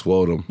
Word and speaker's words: Nwodim: Follow Ego Nwodim on Twitter. – It's Wodum. – Wodum? Sheesh. I know that. – The N is silent.